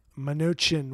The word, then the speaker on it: Mnuchin: Steven Mnuchin. Am I pronouncing his name right? – Mnuchin. We call him the ugly guy.